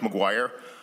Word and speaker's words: Maguire: My name is Mike McGuire. That's spelled M-A-G-U-I-R-E.